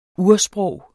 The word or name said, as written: ursprog